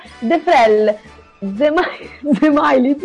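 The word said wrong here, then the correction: Džemaili: La pronuncia corretta dovrebbe essere perché lo sentivo pronunciare in due nomi, in due modi. – 10.000 modi. – No, Gemaili. – Lui ha sempre detto Gemaili. – Gemaili, esatto.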